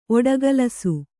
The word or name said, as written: ಒಡಗಲಸು